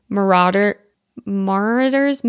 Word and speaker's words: Marauder's: In British English, this is normally pronounced as marauders. You do want to stress on the second syllable.